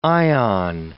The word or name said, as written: ion